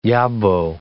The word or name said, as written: yobbo